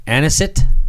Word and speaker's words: Anisette: A-N-I-S-E-T-T-E. Anisette? – Yeah. – Anisette.